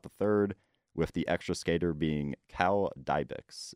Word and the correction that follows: Dybis: Is that how you pronounce his name? – Dybis. – There we go.